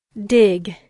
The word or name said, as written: dig